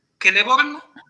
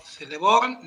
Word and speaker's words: Celeborn: La C. Siempre se pronuncia como K. Eso es lo importante. – Siempre casa, nunca cereal. – Muy bien, perfectamente. Por eso, siempre Celeborn y no Celeborn, ni Celeborn, ni nada por el estilo.